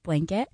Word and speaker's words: B7LANKET: Their Blanket, that is spelled B7LANKET, but pronounced Blanket.